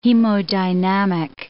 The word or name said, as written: hemodynamic